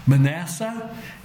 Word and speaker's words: Manasseh: Manasseh, you spell that M-A-N-A-S-S-E-H. Manasseh. And Ephraim is E-P-H-R-A-I-M.